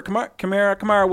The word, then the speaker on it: Kamara: he's not getting in where Kamara, whatever it is. I got to learn to pronounce it correctly. – Don't feel bad, by the way, because it's K-A-M-A-R-A, but it's pronounced K-A-M-E.